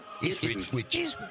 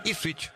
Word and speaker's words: Ipswich: Ipswich. – Ipswich.